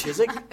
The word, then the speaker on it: Chiswick: It's pronounced Chiswick.